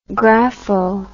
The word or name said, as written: grapho